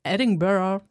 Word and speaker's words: Edinburgh: Eigentlich wollte sie in Edinburgh Edinburgh, so spricht man es aus. – Ja, ich glaube schon.